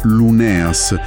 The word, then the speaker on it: Luneas: Esatto, e questo ci porta dritti a Casa Luneas, e attenzione, qui alla pronuncia è Luneas, e al lavoro di Virginia Luneas, anche qui, Luneas.